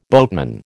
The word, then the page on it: boatman: American English